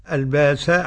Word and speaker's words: البأساء: قرأ بإبدال الهمزة البأساء قرأ بالإبدال السوسي والإمام أبو جعفر. – نعم. – أما ورش لا يبدلها. – نعم. – ويبدلها أيضا الباساء حمزة إذا وقف الهمزة المتطرف.